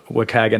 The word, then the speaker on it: WCAG: So there's a set of standard guidelines, the Web Content Accessibility Guidelines, or WCAG, as you helped me understand that's how it's pronounced shortly. Talk a little bit about the WCAG and how it impacts your work, and what role public policy actually plays in helping to make the internet more accessible.